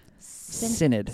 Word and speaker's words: Synod: That's how you pronounce that. Synod.